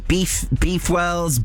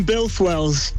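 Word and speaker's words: Builth Wells: Is it Beefwells, Boothwells? Put me out of my misery. – We're in Bilthwells.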